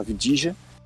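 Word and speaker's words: Avdija: Então, pick 4 do Bulls, Avidia. Avidija, né? Na verdade, essa é a pronúncia correta, Avidija.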